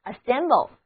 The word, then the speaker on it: assemble: A-S-S-E-M-B-L-E assemble, 字母 A 要读作短的 A, 字母 E 要读作短的 A, 然后合在一起 assemble 就是聚合、聚集、装配的意思。